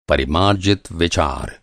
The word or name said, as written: परिमार्जित